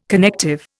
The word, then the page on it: connective: Indian